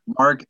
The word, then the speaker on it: Marc: Mark, M-A-R-C, Poulet, H-O-U-L-E.